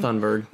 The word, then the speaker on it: Thunberg: But then I thought about Greta Thunberg, or however you pronounce her last name. – We're going with Thunberg.